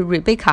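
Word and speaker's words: Rebekah: 然后当时呢，就是他的女儿，如果他的女儿的名字我没有发错音的话，就是 Rebecca 哈，sorry 了，H 不发音，Rebecca。然后呢，她是特朗普的一个。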